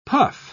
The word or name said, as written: puff